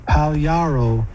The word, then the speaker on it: Pagliaro: Now I'm uncertain of the pronunciation there. That song was using his family name there, and he sang it throughout the song, and he pronounces it Pagliaro, which is an Italian name by the looks of it.